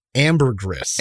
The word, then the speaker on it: Ambergris: How do you pronounce it? – Ambergris.